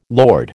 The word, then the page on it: lord: American English